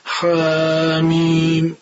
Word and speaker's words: حم: بسم که تجویدی حسابات بهش دقت بکنیم، حامیم اگر یه ذره ما ها رو بکشیم ممکنه بعد تا بخوایم میم رو تلفظ بکنیم یه چیزی شبیه میم مشدد خونده باشیم. اینجا این اتفاق افتاد، گفتیم که حامی حامی، یک لحظه روش مکس کردیم، بعد اینو واضح بشین حامیم، سریع از روی تلفظ حرف میم رد بشید.